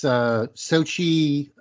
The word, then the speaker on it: Xochitl: It's real simple. It's Xochitl Gomez. – And looking at it, that is not how.